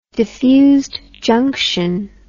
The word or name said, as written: diffused junction